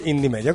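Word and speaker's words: Indymedia: No, romanamente si dice Indie Media, comunque latinamente pure. – L'avrete sentito più volte da questi microfoni questa mattina, è la stessa cosa, non si tratta di due siti diversi, si scrive sempre con la I.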